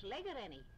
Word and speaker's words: Blairgowrie: How do you pronounce Blairgowrie? Did you say Blairgowrie like I would say Blairgowrie? – Well, down Manx people it's Blairgowrie.